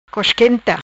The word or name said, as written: Cosquenta